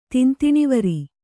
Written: ತಿಂತಿಣಿವರಿ

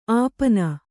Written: ಅಪಿನಿ

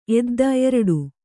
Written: ಎದ್ದ

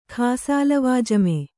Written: ಖಾಸಾಲವಾಜಿಮಾ

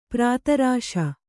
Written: ಪ್ರತಾರಿಸು

